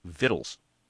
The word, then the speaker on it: victuals: Vittles is spelled V-I-C-T-U-A-L-S, victuals. But if you look it up in the dictionary, what is considered the correct pronunciation, in spite of that spelling, is vittles.